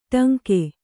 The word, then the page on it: ಟಂಕ: kannada